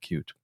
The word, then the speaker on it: Qt: I grew up using Qt without knowing how to pronounce it. People do pronounce it cute, but anyway.